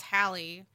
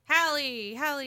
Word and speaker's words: Halle: It's Halle. Halle. It's distinct. – Two syllables.